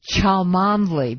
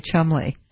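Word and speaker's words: Cholmondeley: For example, there's one if you do the phonetic spelling, it's Chalmondly, but that isn't how you say it. You say Chumley.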